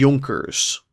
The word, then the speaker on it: Junker: We call the upper landowning class the aristocracy of the Prussian kingdom, the Junkers. And that's spelled like Junker. If you don't speak German or are familiar with German, you have that J.